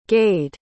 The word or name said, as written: gade